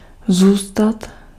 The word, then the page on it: zůstat: /zuːstat/